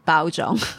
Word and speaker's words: Baozhong: So Baozhong pronunciation is Baozhong.